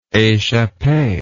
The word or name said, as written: echappe